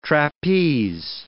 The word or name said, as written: trapeze